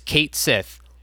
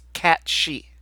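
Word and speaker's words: Cait Sith: His name isn't Kate Sith, it's Cat She. It's how it should be pronounced,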